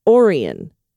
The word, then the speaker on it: Orion: I heard you mention Lake Orion the other day. And in true Michigander fashion, it's not pronounced like the constellation. It's Lake Orion.